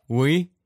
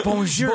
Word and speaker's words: bonjour: Oui? – No, it's yes. It's bonjour.